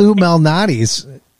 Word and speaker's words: Lou Malnati's: But I've been ordering in from Lou Malnati's. Is that how you pronounce it? – Lou Malnati's. Yes.